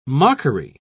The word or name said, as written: mockery